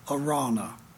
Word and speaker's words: Araunah: if you take the U out of the name and put in a W, it'll help you to pronunciate that word. A raw, like R-A-W, raw, Arana. So now you know how to pronounce that, okay?